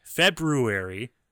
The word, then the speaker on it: February: The thing about February is it's actually, I believe, supposed to be pronounced February.